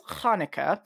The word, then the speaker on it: Hanukkah: So the Hebrew pronunciation is Hanukkah. If I say that, I mean the same thing.